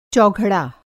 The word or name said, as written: चौघड़ा